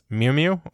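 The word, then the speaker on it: Mjölnir: The MCU pronunciation for Mjolnir, which is how I'm pronouncing it, is Mjolnir. Okay. Although, if you're Darcy Lewis, she pronounces it a little different. She'll pronounce it as Mew-Mew or Mew-Ma, is the other option they put here.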